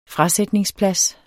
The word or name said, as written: frasætningsplads